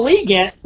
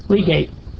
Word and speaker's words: legate: They captured Braziers when Simon de Montfort, Earl of Leicester, asked the abbot of Chouteau, the papal legate, how do you say that word? Legate. – Legate. – That's good. When I hear it, I know it.